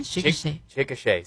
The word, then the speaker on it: Chickasha: Esto está por allá en Chickasha. – Oh, sí. No. – ¿Lo pronuncié bien? – Chickasha, sí.